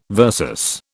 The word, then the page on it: versus: British English